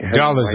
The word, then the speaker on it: Dolezal: The Rachel Dolezal, am I pronouncing her right name? – Dolezal, right? – Yes.